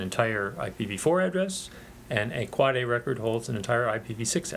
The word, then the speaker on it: AAAA: And you do pronounce it Quad A.